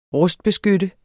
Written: rustbeskytte

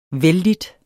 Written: vellidt

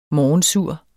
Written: morgensur

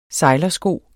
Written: sejlersko

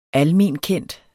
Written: almenkendt

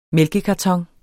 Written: mælkekarton

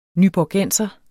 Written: nyborgenser

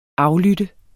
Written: aflytte

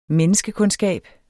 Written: menneskekundskab

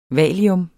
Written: valium

